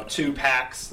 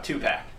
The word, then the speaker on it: Tupac: You know, two packs, like Tupac the rapper.